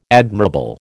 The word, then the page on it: admirable: American English